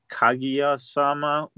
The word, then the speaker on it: Kaguya-sama: Although I think they pronounce, I just wanted to point this out, the way you pronounce it, I think they pronounce it Kaguya-sama with the emphasis on the ka instead of the gu, but that's just what I noticed.